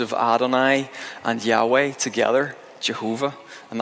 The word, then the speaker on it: Adonai: which is pronounced Adonai, and effectively how that morphed in English was that it effectively became Yehovah.